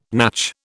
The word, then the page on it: natch: British English